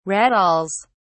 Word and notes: rattles: British English

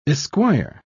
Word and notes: esquire: British English

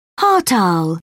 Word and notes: hartal: British English

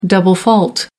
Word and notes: double fault: American English

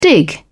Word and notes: dig in: British English

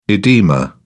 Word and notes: edema: British English

